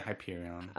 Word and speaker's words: Hyperion: killed by Hyperion. Uh, am I saying that right? – Yeah, Hyperion.